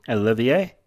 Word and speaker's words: Olivier: Olivier? – Yeah, Olivier, like Lawrence. – Olivier.